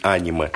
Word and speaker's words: аниме: чтобы сказать, что правильно говорить аниме.